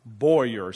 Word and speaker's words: Buea: What we're doing is that we are planting a brand new church in Bua or Boyer. I think that's how they say it. B-U-E-A. Boyer is how they say it.